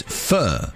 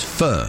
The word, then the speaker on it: pho: The most popular dish is pho. Apparently, that's how it's said over there. We might call it pho over here.